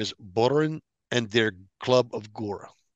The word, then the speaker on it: Bohren: I'm going to spell it for you. – Yeah, thank you. – B as in boy. – Uh-huh. – O-H. R, E as in Edgar, N as in Nancy. You should have Bohren.